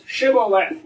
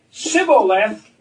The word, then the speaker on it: shibboleth: And when they came to the river, they said to them, because they didn't pronounce the SH in that language, they would say to us, shibboleth. And if the guy said, sibboleth, they ran him through.